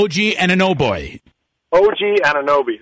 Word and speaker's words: OG Anunoby: You mentioned Siakam, also Ojinobi. How do I pronounce his name? – OG Anunoby.